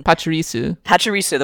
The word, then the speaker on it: Pachirisu: Well, then again, the Lawnmower one got demolished in that video of a Pachirisu. How do you pronounce that name? The little squirrel Pokemon. – Pachirisu, the one where he demolishes the Salamence and...